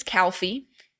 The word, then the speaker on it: Calfe: Our mascot's name is Calfee. Same name as the park, okay. But we spell it C-A-L-F-E, kind of like a calf.